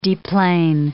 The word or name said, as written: deplane